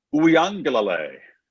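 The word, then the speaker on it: Uiagalelei: Uyangalale.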